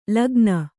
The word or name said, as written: ಲೇಖನ